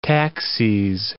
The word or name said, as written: taxes